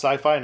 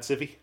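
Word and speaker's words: Syfy: I remember those good old days when it was actually pronounced Sci-Fi, not Siffy.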